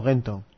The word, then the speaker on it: Gentoo: ¿Gentoo o Gentoo? – Gentoo. Es que es OO, al final acaban OO, entonces yo lo pronuncio como U, ¿no?